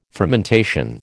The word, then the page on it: fermentation: American English